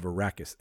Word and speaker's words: Arrakis: Duke Leto is the ruler of an ocean planet, Caladan, accepts a relocation to a harsh desert planet of Arrakis.